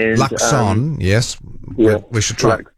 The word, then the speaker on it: Luxon: No T in his name. – Didn't say Luxton. I said Luxton. I did say that. Yeah, I heard a T there. I almost said it wrong.